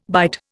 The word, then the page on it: bight: Indian